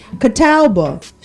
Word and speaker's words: Catawba: Catawba? – Where is that at? – Catawba County. Catawba.